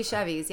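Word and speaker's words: Chevys: Became a busser, did the dishwashing thing, Do you guys remember Chevy's, that Mexican restaurant that used to be here? – Oh, absolutely. – I remember Chewy's. I don't remember Chevy's. – It was actually Chevy's. Yeah, like Chevy's. C-H-E-V-Y-S.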